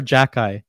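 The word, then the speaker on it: Xhekaj: And I said, Arbor Jack Eye, spelt or pronounced Jack Eye.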